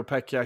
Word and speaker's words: Pecchia: And under their new manager, I want to say his name is Fabio Pescia, isn't it? – Fabio Pecchia.